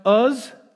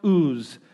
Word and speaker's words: Uz: Job lives in a land spelled U-Z. I've heard it pronounced Uz. I've heard it pronounced Ooze. Personally, I'm going with Uz because Ooze sounds icky.